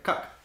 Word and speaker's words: Koch: Koch. I said it right. Koch.